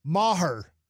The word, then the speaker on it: Maher: Maher. That's how you say his name.